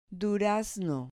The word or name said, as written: durazno